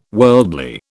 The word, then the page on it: worldly: British English